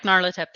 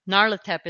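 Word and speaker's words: Nyarlathotep: Narlatep, which I don't know if I'm saying it right. – That sounds right because he's more Egyptian. – Yeah. I do like Narlatep.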